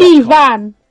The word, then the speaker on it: Ifan: I remember because it's spelled Ifan, but your name is Ivan, and your dad went into a very long, slightly tedious lecture as to why that's the case.